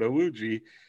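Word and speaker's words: Iwuji: Naval officer and NASCAR driver Jesse, I should have looked up the pronunciation. – Awuji. – You looked it up. You're good. Awuji.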